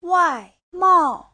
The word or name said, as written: wai4mao4